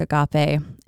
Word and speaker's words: agape: Okay, so if you look it up, if you look up agape, A-G-A-P-E, not to be confused with agape, spelled the same way. – Be careful what you're putting in your search engine. – Different word. Exactly.